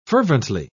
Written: fervently

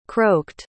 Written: croaked

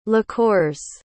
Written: liqueurs

